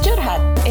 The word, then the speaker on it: curhat: Korhat. Okay, I'm done. – Nope. It's pronounced curhat. – It's an Indonesian term. It literally means rambling about your feelings.